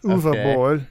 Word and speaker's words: Uwe Boll: Looks like U-Bowl. – It looks like it's U-W-E. – Yeah, Bowl. But it's pronounced Uwe Boll. – B-O-L-L.